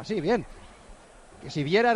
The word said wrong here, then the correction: Siviero: era el entrenador, ¿no? Que tenía el Hércules hace poquito. – Siviero. – Ah, Siviero.